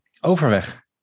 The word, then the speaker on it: Overweg: Overweg.